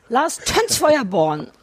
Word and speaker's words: Lars Tönsfeuerborn: Lars Tönsfeuerborn.